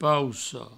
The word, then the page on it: poussa: pousso; provençal